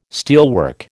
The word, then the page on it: steelwork: American English